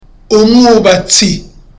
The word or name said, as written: umwubatsi